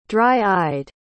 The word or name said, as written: dry-eyed